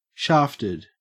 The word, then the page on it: shafted: Australian English